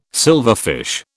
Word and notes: silverfish: British English

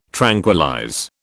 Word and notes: tranquillize: British English

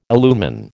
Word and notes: illumine: American English

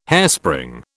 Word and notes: hairspring: British English

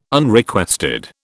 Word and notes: Unrequested: British English

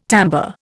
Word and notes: timbre: Indian

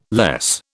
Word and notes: less: British English

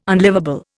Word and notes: unlivable: Indian

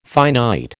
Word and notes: finite: American English

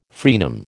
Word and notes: fraenum: American English

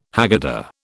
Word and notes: Haggadah: British English

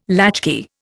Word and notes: latchkey: Indian

